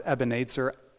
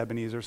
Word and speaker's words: Ebenezer: I've heard it's actually pronounced Ebenezer. We always say Ebenezer, so we'll stick with that.